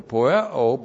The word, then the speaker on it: poieo: And then we have the verb poieo, not only to do. P-O-I-E long O. Poieo, the verb to do.